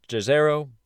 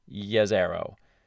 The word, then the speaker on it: Jezero: It looks like it should be pronounced Jezero, but it's Yazero.